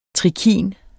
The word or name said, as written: trikin